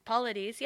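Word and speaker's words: Paulides: Am I saying his name right? – Polides, yeah.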